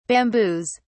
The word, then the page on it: bamboos: British English